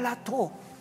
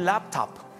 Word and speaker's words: laptop: No, dijo, una lato. ¿Qué es eso? De esas computadoras que se abren así. O un laptop.